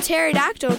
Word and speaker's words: pterodactyl: Why can't you hear a pterodactyl go to the bathroom? Because the P is silent.